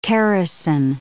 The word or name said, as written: characin